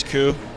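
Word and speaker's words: Ku: My name is Koo.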